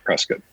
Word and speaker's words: Prescott: And now I'm semi-retired in Prescott, Arizona, but we pronounce it Prescott, and been a Canon Explorer of Light for 18 years, I think.